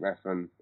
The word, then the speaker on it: Refn: I can't say his name, Refn, is it? – Yeah, The Neon Demon.